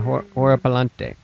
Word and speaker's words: Horripilante: Horripilante? – La Horripilante.